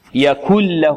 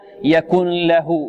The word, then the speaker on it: يكن له: فكأننا ننطق كافا وبعدها لام مشددة هكذا. يكله يكل له كل يكل له وليس يكن له يكن له. بعض الطلبة يقول يكن له يعني يأتي برائحة النون أو يظهر النون وهذا خطأ.